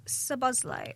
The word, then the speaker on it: Szoboszlai: Well, Dominic Szoboszlai.